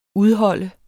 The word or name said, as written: udholde